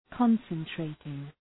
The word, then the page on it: concentrating: {‘kɒnsən,treıtıŋ}